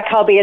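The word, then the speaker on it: Kubby: Yeah, Sky Cubby.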